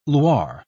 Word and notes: loir: British English

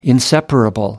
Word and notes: inseparable: American English

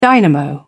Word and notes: dynamo: British English

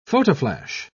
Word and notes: photoflash: British English

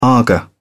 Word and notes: aga: British English

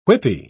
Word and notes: whippy: British English

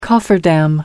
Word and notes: cofferdam: American English

